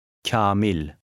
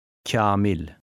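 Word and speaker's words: Kâmil: Kamil. – The long A affects the sound of a preceding K, making it sound softer, somewhat like kya or cha. Try to read number 17. – Kamil.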